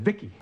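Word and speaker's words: VICI: V-I-C-I, I, I pronounce it Vicky.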